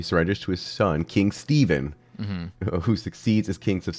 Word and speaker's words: Stefan: 1331, King Stefan Uros III, after months of anarchy, surrenders to his son and rival, Stefan Dusan, who succeeds. – No, you're not pronouncing it right. It's Stephen.